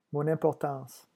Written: mon importance

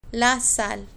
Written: la sal

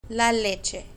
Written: la leche

